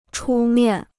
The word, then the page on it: 出面: chū miàn